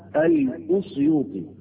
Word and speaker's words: الأسيوطي: في الأصل أو في الحق الكلمة اسمها الأسيوطي، وهي تنطق بضم الألف، واللي من الصعيد لابد من نطقها نطقا صحيحا تقال أسيوط.